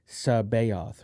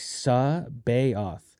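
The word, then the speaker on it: C'baoth: So it was like Sabayoth. And so that's what the fan was like, hey, this is how you pronounce it because that's what Timothy Zahn said how to pronounce it, right?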